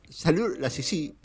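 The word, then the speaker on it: Saliou Lassissi: Saliu Lassisi es un futbolista de Corta de Marfil. Lo pronuncia así porque también es francés, tiene la doble nacionalidad, ah.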